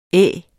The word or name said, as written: Æ